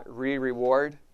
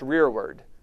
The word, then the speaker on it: rearward: If I want to know what Jesus means when he says that he will be my rearward, that's how you pronounce that word, by the way. It's not re-reward, it's rearward.